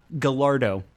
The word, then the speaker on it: Gallardo: That's what I thought it was pronounced as. Gallardo. Because in Italia, they don't do the yeah for double L's.